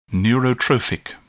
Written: neurotrophic

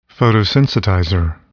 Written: photosensitizer

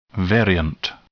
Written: variant

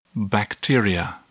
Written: bacteria

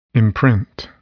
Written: imprint

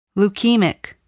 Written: leukemic

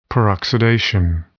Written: peroxidation

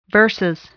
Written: versus

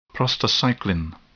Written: prostacyclin

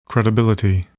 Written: credibility

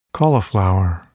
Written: cauliflower

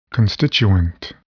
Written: constituent

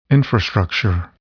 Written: infrastructure

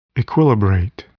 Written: equilibrate

